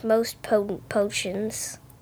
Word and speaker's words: Moste Potente Potions: Oh, I thought it was just Most Potent Potions. – Well, I think you would pronounce it that way.